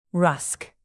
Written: rusk

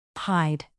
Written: pied

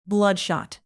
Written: bloodshot

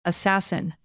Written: assassin

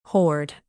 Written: horde

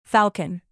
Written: falcon

